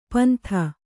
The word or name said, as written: ಪಿಂದು